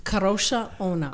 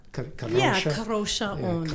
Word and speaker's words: Kadrolsha Ona: Kadrolsha Ona. – Kadrolsha. – It's got kind of a rolling okay. R. – Kadrolsha Ona.